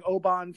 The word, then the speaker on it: Oban: So I noticed that you're pronouncing it Oban, which sounds like that's probably how it's actually pronounced in Scotland. – Yeah. – As long as I've been bartending, everybody calls it Oban because that's how it looks like it's spelled. But yeah, it's phonetically it's, you know, it's spelled O-B-A-N, but it's pronounced in Scotland, it's Oban, the town of Oban.